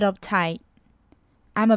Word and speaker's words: uptight: 还要注意到的是 uptight 字尾的 t 呢，因为在句子中间，所以呢我们的字尾 t 的发音可以省略，I'm a bit uptight，I'm a bit uptight。